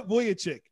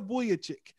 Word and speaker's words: Vucevic: I think uh Voychich is oh I probably butchered his name. Nick, it's Again. – Nikola Vucevic. – I had I, it came to me as soon as I like disconnected. I was like, it's Nikola Vucevic.